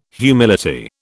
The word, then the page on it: humility: British English